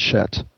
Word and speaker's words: Set: In fact, in ancient Egypt, we're not quite sure how they pronounce Set, and some scholars believe it was pronounced Shet.